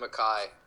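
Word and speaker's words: Mekhi: I think he likes to be called Mackay.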